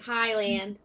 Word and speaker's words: Highland: Just like Highland.